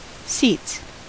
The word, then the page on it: seats: American English